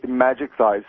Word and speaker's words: Majikthise: There's a blog called Magic Thighs. Now, this is not porn. – Magic Thighs? – Magic Thighs. Now, this is brilliant. This is good. Magic Thighs, spelled M-A-J-I-K-T-H-I-S-E. Obviously a pun, Magic Thighs. – How do you spell it? M-A-J? – M-A-J-I-K-T-H-I-S-E.